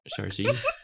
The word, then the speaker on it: chargeur: Or if it might also be pronounced the French way. – Charge. – I don't know. Chargeur. – Yeah. – You got to get the guttural R Charger. In there. – Chargeur.